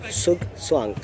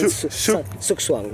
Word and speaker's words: Zugzwang: So the band is pronounced ZugZwang. So imagine like Sun ZugZwang.